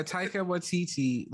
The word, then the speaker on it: Taika Waititi: Yeah, Taika Waititi.